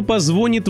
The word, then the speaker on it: звонит: Вы ему отвечаете: «Дядя Вася, правильно говорить, позвонит!» Но дядя Вася не дурак, он достает орфоэпический словарь, открывает нужную страницу и показывает, что вариант «звонит» вполне допустим в разговорной речи.